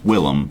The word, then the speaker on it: Willem: No, Defoe. Well, it's Willem.